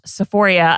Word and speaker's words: Sephoria: Yes, again, that's pronounced Sephoria. I know one time we talked about that and people thought I didn't know how to say the word Sephora, but Sephoria is...